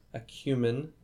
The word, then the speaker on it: acumen: We already talked about Kubrick's directorial acumen, and that's a correct pronunciation.